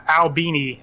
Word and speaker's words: Albany: And Albini, as apparently it's pronounced in that area, which is weird to me. But it's like Albany, like New York.